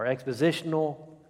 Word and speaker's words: expositional: If you'd like to follow along with this study, we're using outlines from the expositionary or expositional, Christ-centered expositional commentary.